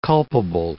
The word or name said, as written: culpable